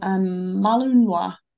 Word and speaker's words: Malinois: The second half of the name of this Belgian guard dog breed tends to be botched, as many mistakenly rhyme Malinois with Illinois, which is literally what we just said. However, the Oise is pronounced Wa, often called Mal.